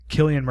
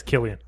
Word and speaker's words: Cillian: And main character is Killian Murphy. – Correct. – Or Cillian Murphy. I don't even know how to say his name. – I'm going to say it's Killian. – I think it's Killian.